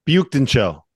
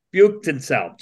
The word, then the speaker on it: Büyükdinçel: I mean, I look at the almanac, and for Mick Cronin to say if Burke Wyatt-Tunsil, the 6'9" forward from Turkey. – Büyükdinçel. Burke Büyükdinçel. – Thank you. I just totally butchered that. Totally butchered that. – You weren't even close. I was like, who's he talking about? Oh, okay, Burke. – Thank you. Büyükdinçel.